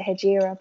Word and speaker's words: Hegira: I don't, yeah, I think I always pronounce it Hegira, but I've heard people say it different ways.